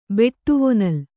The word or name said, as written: ಬಾಡವಾನಳ